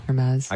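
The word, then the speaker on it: Hermès: Is it Hermes? – Hermes. – I called it Hermes before, but people corrected me. It is Hermes. – Hermes, maybe? – Hermes.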